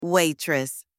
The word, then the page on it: waitress: anglais